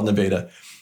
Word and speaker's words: Nevada: It's spelled Nevada, but in Iowa, we call it Nevada.